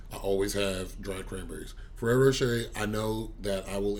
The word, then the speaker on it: Ferrero Rocher: You know you gotta roll the R like our Ferrero Rocher.